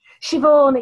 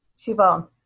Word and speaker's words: Siobhan: Siobhan, it's an Irish name. – Siobhan. – Yeah, Siobhan. Irish. Siobhan, like Yvonne, Siobhan. You can say Siobhan if you're posh.